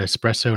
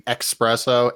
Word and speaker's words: espresso: Just want to compliment Steve's pronunciation of espresso and not saying expresso and exhaust like an idiot.